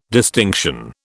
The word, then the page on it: distinction: British English